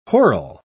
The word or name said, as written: whirl